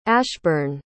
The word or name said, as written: ashburn